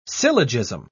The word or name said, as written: syllogism